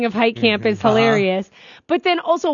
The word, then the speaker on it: Hieghcamp: I think it's Hike Camp. – Okay. – And it's spelled H-I-E-G-H-C-A-M-P. – Hike Camp. – Gamp? What? – Hike Camp. H-I-E. Usually, I could see the H-E-I, but no.